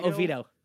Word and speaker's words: Oviedo: And you can talk more about like what's your vibe when you guys meet up at like Arcade Monsters in Oviedo, if I said that right. Oviedo? Oviedo. – Uh, Oviedo.